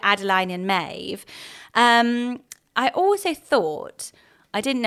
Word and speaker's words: Maeve: I really liked Maeve for her. It's a one syllable name, actually.